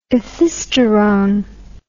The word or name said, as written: ethisterone